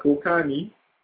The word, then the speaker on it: Kokanee: Kokani. – What? – Yeah, it's spelled K-O-K-A-N-E-E. – Wasn't there another name like that before? – Yeah, well, and it sounds like cocaine.